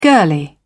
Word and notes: girlie: British English

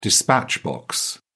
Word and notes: dispatch box: British English

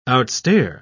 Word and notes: outstare: British English

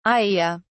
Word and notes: iaea: British English

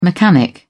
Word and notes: mechanic: British English

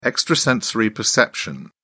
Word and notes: extrasensory perception: British English

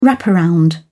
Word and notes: wraparound: British English

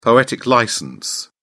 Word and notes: poetic licence: British English

